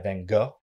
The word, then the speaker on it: Van Gogh: It's paintings by Van Gogh, by Van Gogh. – that's the proper way to say it.